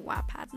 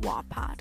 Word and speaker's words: Wattpad: Yes, I pronounce it Wattpad, not Wattpad, because Wattpad sounds fucking weird.